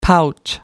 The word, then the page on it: pouch: American English